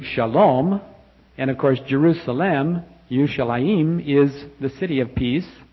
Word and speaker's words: Yerushalayim: When we think of Jerusalem, the correct pronunciation is really Yerushalayim. I'm not sure I can do that in the morning. My tongue's not ready for Yerushalayim.